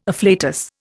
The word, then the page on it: afflatus: Indian